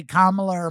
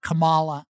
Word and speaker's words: Kamala: Speaking of yarmulkes, kind of rhymes with Kamala, which rhymes with Mamala. And anybody can learn how to say Kamala or Mamala, except Michael Vanderveen, the big white lawyer that Donald Trump hired, who calls her Kamala.